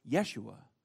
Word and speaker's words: Yeshua: She will name him Jesus, or Joshua in the Hebrew. It's pronounced Yeshua, a name that literally means "the Lord saves."